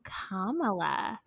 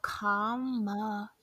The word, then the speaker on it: Kamala: I don't know what I was saying, but someone broke it down to me like this the word comma that separates two sentences, Allah. So Kamala.